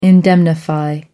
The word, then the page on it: indemnify: American English